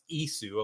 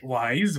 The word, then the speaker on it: Ys: And I remember you were like, oh, yeah, it's pronounced Isu. I'm like, oh, OK. – Wise? What's Wise? – Yeah, I'm sure everybody called it Wise, right?